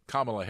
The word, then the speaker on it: Kamala: Regarding my discussion of Kamala Harris, and by the way, that's how she pronounces it, really great.